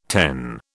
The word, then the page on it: ten: British English